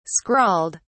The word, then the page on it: scrawled: British English